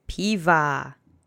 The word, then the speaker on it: piwa: Okay, the next word is piwa, which means beer. It's the singular object form of piwo, which is beer. Let's say piwa together three times. The accent is on the first syllable.